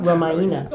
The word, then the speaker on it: Ramayana: Ramayana.